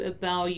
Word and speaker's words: about: I think this is a good opportunity to talk about the pronunciation of the word about. Normally, we don't really pronounce the T in about, right?